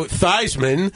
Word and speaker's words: Theismann: Joe Theismann. – Yes. – Changed it to Joe Theismann to rhyme with Heisman, yeah.